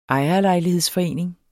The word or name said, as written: ejerlejlighedsforening